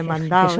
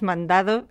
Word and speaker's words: mandado: Bueno, ya sabemos, hemos dicho muchas veces que la D entre vocales se pierde y en esta sílaba final más. Y en este caso se pierde tanto que podríamos decir que lo normal, por lo menos en el español europeo, no es mandado, sino mandao.